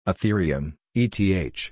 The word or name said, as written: Ethereum (ETH)